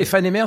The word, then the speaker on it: Aephanemer: E e donc, Ephanémères, e ça s'écrit A-E-P-H-A-N-E-M-E-R.